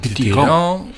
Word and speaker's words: Dithyramb: Met een E, A-M-B-E of A-M-B gewoon? – Gewoon A-M-B. Ah, heb ik Dithyramb ergens anders zien staan. – Ik kan het zelfs zonder B uitgesproken. – Ah, dus dan is het Dithyramb, nummer 3, en fa majeur.